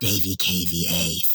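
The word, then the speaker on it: DAVEYKVA: It's, I think it's D-A-V-E-Y-K-V-A. He pronounces it Davy K-V-A, thinking it's clever, but it's not.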